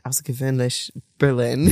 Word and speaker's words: AusserGewöhnlich Berlin: And so in your network in AusserGewöhnlich Berlin.